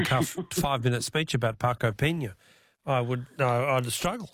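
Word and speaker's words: Peña: Marco Pena with a little accent on the N, Pena.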